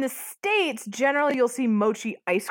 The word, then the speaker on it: mochi: Did I say it wrong again? Oh, from Mochi Mochi Boy. Mochi, yes.